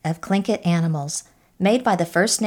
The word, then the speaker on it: Tlingit: Tlingit sounds like K-L-I-N-K-I-T, but it's spelled T-L-I-N-G-I-T.